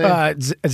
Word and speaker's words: Zabka: She wants to pull away from Zabek, how do you say his name? – Zabka.